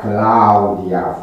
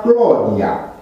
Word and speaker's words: Claudia: Deci este limpede că romanul de viță nobilă pronunța Claudia frumos, iar romanul de pe stradă pronunța Clodia la fel de frumos.